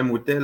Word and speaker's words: pimouteo: Moutet, moutet, là, c'est euh, le vrai mot, c'est pimouteo. Mais le, P, le P est devant, euh, est invisible, on ne le prononce pas, fait que ça sonne moutet elle, elle dit de cette façon-là, puis j'imagine que des fois, ça doit.